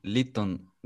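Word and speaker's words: Lytton: Lin diye mi okunuyormuş? Biz Litton diye veriyorduk sanki. – Litton. Pardon, tamam. Yanlış söyledim ben. İyi ki düzelttin. Litton.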